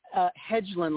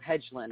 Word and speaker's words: Hedlund: Hedlund like hedges. So Hedlund.